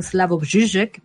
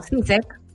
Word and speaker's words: Slavoj Žižek: Por ejemplo, el esloveno Slavoj Žižek, porque yo aprendí a pronunciarlo. Ay, me gusta. Slavoj Žižek. ¿Cómo? – Más conocido como Zizek, pero bueno.